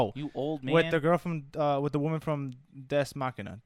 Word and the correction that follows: Ex Machina: How do you say that movie? – Ex Machina? – Ex Machina. The robot girl from Ex Machina.